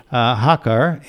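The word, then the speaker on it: Hakha: His diocese is Hakkar. Did I say that right, Father? – Yes, that's right. – Okay. Hakkar, and that diocese borders India.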